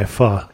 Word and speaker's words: pho: And I see that I can also get my pho there if I'm so inclined. – Oh, yeah. Yeah, you can. – The Vietnamese soup. It spells a P-H-O, but not pronounced that way.